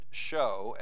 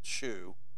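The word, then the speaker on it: shew: But we're not going to be, as I said, we're not going to be like Ed Sullivan and pronounce it shoe. We're going to call it show.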